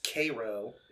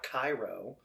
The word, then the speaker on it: Cairo: It's Cairo, Illinois, not Cairo. – Oh. – Yeah, spelled the same if I remember correctly.